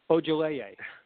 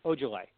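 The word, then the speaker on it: Ojeleye: Ojoleye. Ojoleye. Semi Ojoleye. – Ojoleye.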